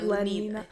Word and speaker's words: Lenina: Lenina? – Yeah, Lenina.